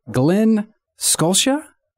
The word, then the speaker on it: Glen Scotia: Glen Scotia.